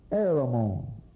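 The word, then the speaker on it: Heremon: This eastern princess was married to King Heramon, usually with a silent H, Heramon,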